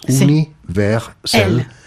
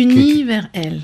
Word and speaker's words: Universel: Que hay que pronunciarlo en tres sílabas. Universel. – Sí. Universel.